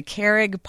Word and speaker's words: Carrig: I've been to the I don't know how to say it, though, the Carrig Pub? – You got it.